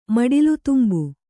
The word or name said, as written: ಮಡಿಲುದುಂಬು